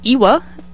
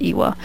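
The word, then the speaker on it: EWY: Next up, we've got Woolen Mittens by Ewa. That's how I'm choosing to pronounce it. It's E-W-Y, and I'm choosing to pronounce that as Ewa.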